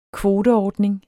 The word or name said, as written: kvoteordning